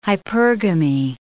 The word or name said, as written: hypergamy